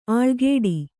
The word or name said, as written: ಅಳ್ಕಾಡು